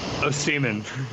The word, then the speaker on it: Osimhen: Osimhen.